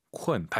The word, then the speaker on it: 困: K-U-N인데 U-N 사이에 어 들어가야 돼요. 쿤쿤 하지 마시고 쿤. 쿤.